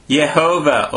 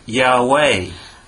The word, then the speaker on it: Yahweh: The traditionally accepted way of pronouncing this name was Yehovah, or in English, Jehovah. – 伝統的にこの、 YHWH、これは、エホバと発音、英語ではジェホバになりますが、エホバと発音されてきたんです。 – But nowadays, many scholars think that this word might have been pronounced Yahweh.